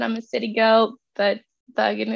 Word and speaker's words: Bergen: Okay, so you were born in Bergen, Norway? – Yes. Is that how you say it? Bergen, Norway, yes.